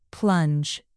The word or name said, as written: plunge